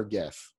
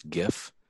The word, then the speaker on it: GIF: I got to ask you before we start, do you pronounce it GIF or GIF? – I pronounce it the correct way. It's GIF.